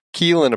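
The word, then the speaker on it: Caoilfhionn: Keelan apparently is the name of several Irish saints as well. So there you go. – It's spelled like another. – Caolfione. Caolfione. Is it spelled like that? – It's the one that it's the one that you wrote down here. – Would you in a million years have gotten Keelan out of that? – No.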